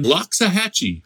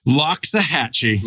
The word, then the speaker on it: Loxahatchee: Loxahatchee. – Loxahatchee. – The famous Loxahatchee. – How do you spell that? Just like it sounds. – It really is. Spell it. Spell it out. – It's L-O-X-A-H-A-T-C-H-E-E.